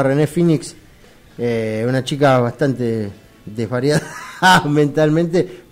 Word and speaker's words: Phoenix: Recordemos que decimos Cash, Phoenix porque Phoenix. Sabemos pronunciar bien el inglés. – Obviamente, pero es no estudié el pedo de los 15 años de mi vida. Inglés. – Es P-H-O-N. No, E-N-I-X. Phoenix.